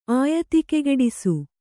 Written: ಆಯತಿಕೆಗೆಡಿಸು

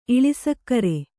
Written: ಇಳಿಸಕ್ಕರೆ

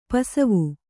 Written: ಪಸಿವು

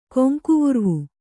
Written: ಕೊಂಕುವುರ್ವು

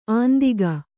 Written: ಅಂದಿಗ

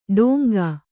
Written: ಡೊಂಕ

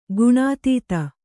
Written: ಘನೋದಧಿ

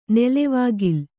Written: ನೆಲೆವಾಗಿಲ್

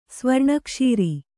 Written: ಸ್ವರ್ಣಕ್ಷೀರಿ

